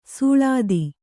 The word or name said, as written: ಸುಳಾದಿ